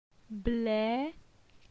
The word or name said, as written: Ble